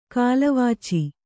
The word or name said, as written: ಕಾಲವಾಚಿ